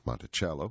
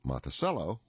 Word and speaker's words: Monticello: You can pronounce it Monticello, which is Italian for little mountain, or you can pronounce it Monticello, which is American. I will stay with the Italian version.